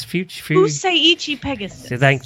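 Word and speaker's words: Fusaichi Pegasus: Is it Futsusaki Pegasus? – Fuseichi Pegasus.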